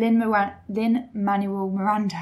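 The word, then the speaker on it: Lin-Manuel Miranda: And Lin, Manuel Miranda, I can never say his name properly.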